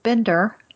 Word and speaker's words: Binder: You commented that Otto Binder, and he puts in parentheses, rhymes with Tinder.